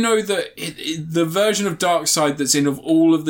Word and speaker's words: Darkseid: Is it pronounced Darkseid? – Yeah.